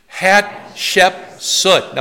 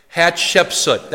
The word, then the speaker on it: Hatshepsut: Let's say her name together. Hatshepsut. Now say it fast once. Hatshepsut. That's how you say her name.